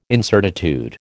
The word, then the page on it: incertitude: American English